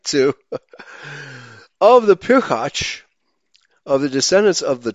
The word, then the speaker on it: pirchach: It's P-I-R-C-H-A-C-H, pirchach, meaning progeny, brood, children, or descendants.